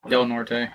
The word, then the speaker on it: Del Norte: Del Norte, yeah.